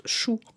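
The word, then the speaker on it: šu: Donc par exemple le signe pour la main qui se prononce chou en sumérien,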